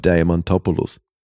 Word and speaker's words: Diamantopoulos: Diamantopoulos.